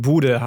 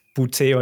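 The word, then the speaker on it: Boutte: Er hat uns auch allen beigebracht, dass es Keishon Bude heißt und nicht Bute oder sonst irgendwas. – Oder Booty.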